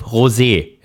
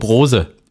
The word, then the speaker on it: Rosé: Das ist genauso wie mit dem Rennstallclub Rosé. Ja? Der wird auch ganz oft als äh, Rennstallclub Rose irgendwie ausgesprochen, weil die ja, auch.